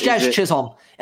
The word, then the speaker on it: Jazz Chisholm: No, it's Jazz Chisholm.